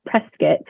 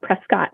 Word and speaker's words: Prescott: My husband grew up in Prescott and he always says it's Prescott, like biscuit, not Prescott. – Yeah, that's right. And we're going to like go from Prescott down south to Tucson.